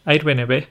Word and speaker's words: Airbnb: que sería Airbnb. – Airbnb. Eso es, AirBnB es lo, más repetido. ¿Cuál sería la forma original? Airbnb. Muy bien, muy bien. Airbnb, pero sí, decimos eh, para el aire, lo decimos air.